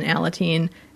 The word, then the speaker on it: Alateen: With that, there's something called Alateen, mm-hmm. I think. Is that how you pronounce it? – Yeah, it's like Al-Anon, right. – And then Alateen.